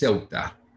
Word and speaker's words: Ceuta: It is Ceuta.